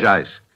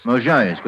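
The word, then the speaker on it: Mozhaysk: Mojaisk, with the accent on the second syllable, eh? – Yes.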